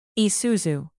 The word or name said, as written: Isuzu